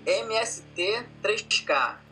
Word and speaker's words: MST3K: MST-KKK, assim? – MST-3K.